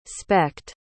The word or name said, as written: specht